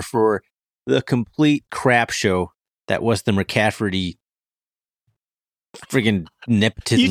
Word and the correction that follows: McCaffery: Is it McCaffery? There's no T? Yeah. I don't know why. Yes, I keep putting a T in there.